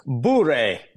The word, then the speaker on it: Bure: So let's you and I take a lesson together in Russian pronunciation. It's Bure.